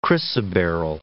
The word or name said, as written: chrysoberyl